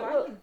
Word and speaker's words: Wang: You pronounce it Wang. – Do you? – Well, it is Wang in Mandarin. It depends whether you do it in Mandarin or Cantonese.